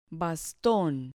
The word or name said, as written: baston